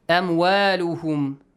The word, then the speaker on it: أموالهم: أموالهم. Make sure you pronounce an A sound. أموالهم ولا أولادهم. Not أموالهم ولا أولادهم. No. Like A sound. Light sound.